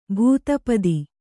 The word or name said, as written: ಭೂತಪತಿ